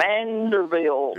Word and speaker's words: Vanderbilt: First Turkey Man, before you get out of here, you said Vanderbilt wrong, so I need you to say it correctly. – Vanderbilt.